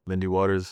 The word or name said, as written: Lindy Waters III